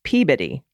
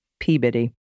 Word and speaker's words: Peabody: The one everyone gets wrong is Peabody. It's Peabody.